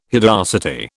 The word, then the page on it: hideosity: British English